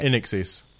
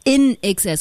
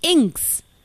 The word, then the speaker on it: INXS: And how do you pronounce that band? Because I've never been aware. – INXS. – INXS. Because I was like, oh, it's Inks.